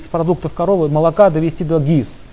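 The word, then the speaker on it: гхи: Итак, значит, еще раз хочу пометить, что ГИ, буква Х не читается, произносится как ГИ, торговая марка Сатвик.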